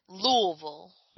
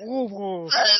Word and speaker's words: Louisville: It's, it's you just, have to real choke on the you. – It's, it's, just the noise. Louisville. you don't say, you don't pronounce any of the word or uh, any of the letters. It's just Louisville.